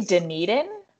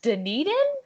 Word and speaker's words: Dunedin: Did you say Dunedin? – Yeah. – Is that how that's pronounced? – Yeah.